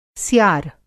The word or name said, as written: स्यार